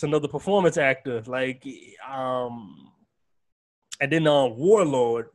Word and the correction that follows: Wardlow: Is, am I saying his name right? I, I feel like I keep saying his name wrong. – Warlord. Yeah, right. Wardlow. – Wardlow. Okay.